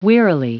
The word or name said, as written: wearily